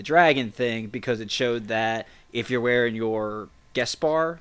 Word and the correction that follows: Beskar: Beskar. – Beskar, yeah. Or say it again, Casey. – Isn't it Veskar? – No, it's B. Like B as a boy.